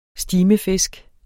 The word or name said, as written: stimefisk